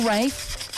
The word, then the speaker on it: Rafe: Little Rafe, yes, I know you can't pronounce it, it rhymes with chafe, which is an ailment Megan has been battling with this year.